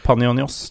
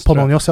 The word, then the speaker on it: Panionios: Panionios. Panionios, ja, i fjor.